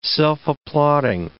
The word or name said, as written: self-applauding